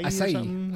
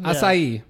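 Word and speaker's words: açaí: That, how do you say that? – Acai. – Acai. Acai. – Yeah. Yeah, I think that's it.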